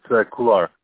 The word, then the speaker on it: Khular: It's Khular.